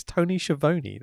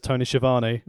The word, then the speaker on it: Schiavone: Can I just also point out that people on TNT Dynamite for AEW constantly pronounce his name wrong, and it really annoys me. Listen to the way says it. It's Tony Schiavone. That's how he says it.